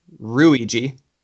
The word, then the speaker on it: Ruigi: Luigi is actually based off of a Japanese name, which this I had to say it in my head and it sounded hilarious. Pronounced Ruigi. No joke. – R-U-I-G-I.